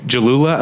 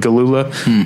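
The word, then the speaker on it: Gelula: You've got a younger band featuring, two great young actresses, Cara Delevingne and Dylan Jalula. Apparently it's a soft G. I've been saying Galula,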